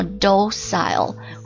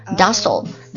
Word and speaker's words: docile: 它可以读作 docile 或者 docile docile docile D-O-C-I-L-E